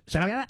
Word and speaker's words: Sharia: I've been mispronouncing it. Sharia law. I didn't know that's how you pronounce it.